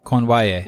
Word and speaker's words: Cournoyer: Cournoyer.